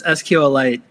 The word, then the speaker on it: SQLite: Yes, it's SQLite, kind of like a mineral.